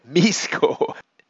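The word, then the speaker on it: Mixco: That's M-I-X-C-O, Misco. Nope, no S in it at all, but that's how they pronounce it.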